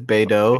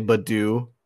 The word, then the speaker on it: Beidou: Her name is Beidou, okay. But I say Badu, like the Pokemon, and he gets angry.